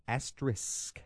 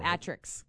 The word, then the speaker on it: asterisk: It's asterisk. – Asterisk. – The proper pronunciation. – It's not Atrix.